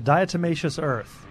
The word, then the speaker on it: diatomaceous earth: Yeah, diatomaceous earth.